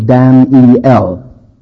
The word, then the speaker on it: Daniel: But every time, you know, the Hebrew word Daniel has three syllables, Dan-E-L. The English word at least two, Daniel. But the American, only one. Have you ever heard of Daniel Boone and Daniel Webster? It's always spelled D-A-N apostrophe L.